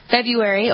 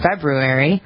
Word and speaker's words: February: When short, February, or if you want to pronounce all those R's, February.